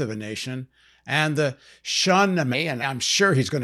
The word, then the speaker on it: Shahnameh: Shah means king. Shahnameh means book. Shahnameh, the Book of Kings.